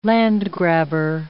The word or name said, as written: land-grabber